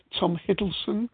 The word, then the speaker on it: Tom Hiddleston: There's something else that Ken put there, a rather shaky video, so be careful, but this is, not sure how you pronounce his name, Tom Hiddleston, is it? – Yes.